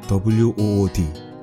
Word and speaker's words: wood: WOOD. 중학생도 아는, 요즘 아마 초등학생, 아니 유치원생도 아는, 너무나 쉬운 단어, 우드. 우드 발음 너무 힘들어요. WOO W와 모음이 만났을 때 이거를 어떻게 해야 되는지 한번 들어보세요.